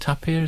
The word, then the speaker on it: tapir: Is it tapir? Is that how we're pronouncing that? Yeah.